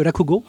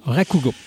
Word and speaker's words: Rakugo: J'ai-tu bien prononcé? – Oui, le Rakugo. – Rakugo.